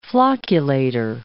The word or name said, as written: flocculator